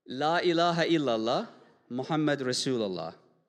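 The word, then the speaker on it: La ilaha illallah Muhammad Rasulullah (لا إله إلا الله محمد رسول الله): Say that again. How do you say it? – La ilaha illallah Muhammad Rasulullah.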